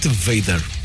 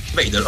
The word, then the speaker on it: Vader: I'm not very good to pronounce things, but I pronounce it Vader. – Vader, okay. Vader. That's the way.